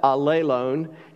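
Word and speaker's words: allelon (ἀλλήλων): And if you want to sound really smart, you pronounce that allelon is how you say that. It's kind of funny. We're not alone. We are together. But allelon is how it sounds.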